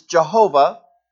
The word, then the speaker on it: Jehovah: And all we know is four consonants, YHWH. And the two popular ways of pronouncing it is Jehovah and Yahweh.